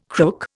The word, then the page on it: crook: Indian